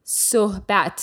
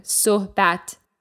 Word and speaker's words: sohbat: This means to speak, and Matt pronounced it exactly right. Sohbat. So there's kind of an H sound in there. Sohbat.